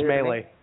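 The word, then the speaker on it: Mallee: Is it pronounced Malley or Maley? I didn't hear it. I believe it's Maley.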